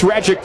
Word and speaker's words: Rajcic: Max Radjic deals home. Outside ball one. Radjic is spelled R-A-J-C-I-C. The pronunciation guide says, like magic.